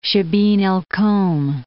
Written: Shiban al-Kawm

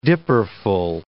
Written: dipperful

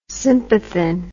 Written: sympathin

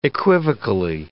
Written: equivocally

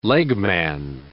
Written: legman

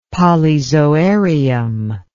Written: polyzoarium

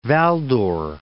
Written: Val-d'Or

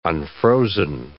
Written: unfrozen

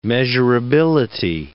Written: measurability

